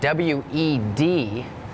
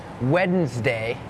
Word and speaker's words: Wednesday: เห็นละครหายใจเฮือกว่าเริ่มเลยก่อนเริ่มรายการพี่โบพูดถึงคำว่าอะไรน้องๆจำได้ไหมคะ Silent letters ใช่ไหมคะนั่นก็คือตัวอักษรที่ไม่ออกเสียงแต่อย่างคำนี้ค่ะเป็นคำหนึ่งที่มี silent letters Wednesday has that D W E D it's not wed Wednesday Wednesday D is silent